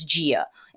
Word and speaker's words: dia: And when I say affricate, what I'm talking about is how the letter D is pronounced like a J. So dia becomes gia. It's not bom dia, it's bom dia.